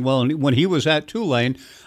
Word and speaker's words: Tulane: And lastly, in terms of new business, it is, in fact, pronounced Tulane, not Tulane.